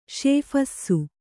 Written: ಶೇಫಸ್ಸು

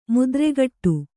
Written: ಮುದ್ರೆಗಟ್ಟು

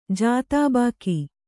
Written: ಜಾತಾಬಾಕಿ

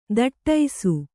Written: ಥಟ್ಟೈಸು

